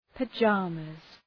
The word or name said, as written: pajamas